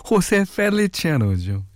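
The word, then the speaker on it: José Feliciano: 네, 호세 페 호세 펠리치아노죠. 예. 레인 들으셨어요. 어, 이런 단어가 몇 있습니다. 아까도 호세 페칠리아노라고 제가 발음을 했는데 어, 베리메닐로우도요.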